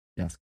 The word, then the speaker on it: Yasuke: Yasuke. – Yeah. – Derived from Isaac. Is how they pronounced Isaac. It became Yasuke.